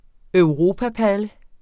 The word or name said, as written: EUROPAPALLE